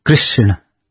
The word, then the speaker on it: Krishna: He said, it is Krishna. It is Chotari. – Mm-hmm. – And he said, if you pronounce wrongly, the meaning changes.